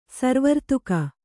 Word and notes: ಸುರವರ್ಧಕಿ: sura vardhaki

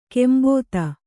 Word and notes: ಕಾಂಬೋದಿ: kāmbōdi

